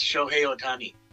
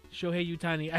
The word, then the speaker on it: Shohei Ohtani: And it's Shohei Ohtani.